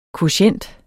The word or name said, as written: kvotient